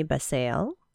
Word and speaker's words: Basile: and it's written by Lisa Marie Basile. I hope I'm saying that right. B-A-S-I-L-E, Basile.